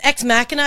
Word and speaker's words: Ex Machina: And it's Ex Machina. I've been pronouncing it wrong the entire time.